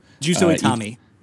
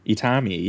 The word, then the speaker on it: Itami: And you might need to correct me on the pronunciation here, but Juzo Itami. – Itami, yes.